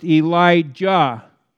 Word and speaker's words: Elijah: Elijah is pronounced Elijah with a hard J, okay? It's not Elijah.